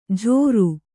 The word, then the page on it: ಝೋರು: Kannada